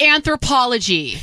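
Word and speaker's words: Anthropologie: It's pronounced Anthropology.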